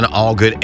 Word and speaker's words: Allgood: I'm Glenn Allgood, A-double-L-G-double-O-D.